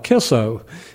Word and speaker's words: Kicco: And one of the places we used to go was called Kisso. It's spelled K-I-C-C-O, and it's pronounced Kisso.